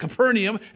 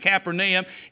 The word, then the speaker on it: Capernaum: We pronounce it Capernaum. After being over there in Israel, they pronounce it Capernaum.